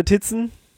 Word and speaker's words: Tizen: Okay, dann der letzte im Bunde, Tizen. – Tizen, da wird es eine neue, oder Tizen, wir wollen es ja richtig aussprechen.